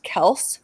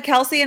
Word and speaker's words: Kelce: He was like, people said Kelce and just ran with it. And so we were just like going with it. But yeah, it's actually pronounced Kels.